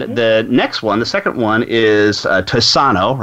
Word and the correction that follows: Tiossan: Right? Am I pronouncing that right? I think it was Tiossan.